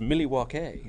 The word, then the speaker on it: Milwaukee: It's pronounced Milwaukee.